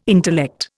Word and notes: intellect: Indian

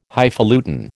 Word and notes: Highfalutin: American English

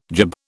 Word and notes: gib: British English

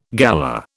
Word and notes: galah: British English

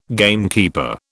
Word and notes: gamekeeper: British English

